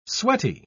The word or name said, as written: sweaty